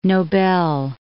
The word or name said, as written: Nobel